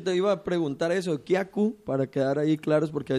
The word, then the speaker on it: Kiaku: Así es muy chistoso porque, bueno, yo aprendí la palabra Kiaku, se pronuncia Kiaku, que es Wixárika, es el saludo Wixárika.